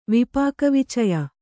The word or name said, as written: ವಿಪಾಕವಿಚಯ